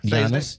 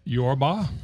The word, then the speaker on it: Giannis: Giannis. Giannis. – What's his name? – Jorma. – Giannis. – It's not Jorma or Jorma.